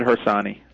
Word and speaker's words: Harsanyi: And David, please pronounce it correctly for us. – That's right. David Harsanyi.